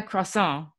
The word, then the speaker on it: croissant: Wait, you're not having a croissant.